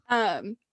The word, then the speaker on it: Thrawn: I want to bring up Thrawn. Did I say that right? – Yes, Thrawn.